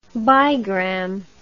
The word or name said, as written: bigram